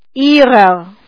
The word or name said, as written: jich